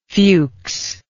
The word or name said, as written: fuchs